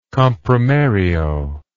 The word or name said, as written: comprimario